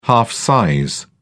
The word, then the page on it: half size: British English